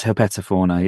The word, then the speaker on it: herpetofauna: I always struggle over the word herpetofauna. – Yes. – That's correct pronunciation, is it? Or pronunciation? – Yes, herpetofauna, yeah. Herpetofauna.